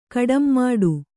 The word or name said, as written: ಕಡಂಮಾಡು